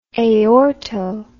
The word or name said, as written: aorto